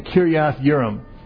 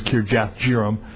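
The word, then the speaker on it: Kiriath Jearim: And then it went from Beth Shemesh up to Kiriath Yearim. And in your Bible it says, like, it's spelled with J's, but that J is pronounced like a Y, Kiriath Yearim versus Kirjath Jearim.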